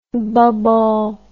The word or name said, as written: bobol